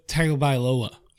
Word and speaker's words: Tagovailoa: Tua Tagovailoa went down. – That's how you say it.